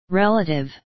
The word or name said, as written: relative